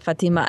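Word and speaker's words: Fátima: I do come from Mexicans and I can only pronounce Latin words as if Fatima is, that's how they say it in Portugal. And Every now and then for my non-Hispanic friends, I'll you know, say Fatima. But I have to say that as a Portuguese woman, I do call it Fatima.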